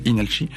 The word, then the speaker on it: Inalchi: Ça s'écrit avec un L, mais ça se prononce Inalchi, qui n'est pas de la K-pop, qui est de la pop coréenne.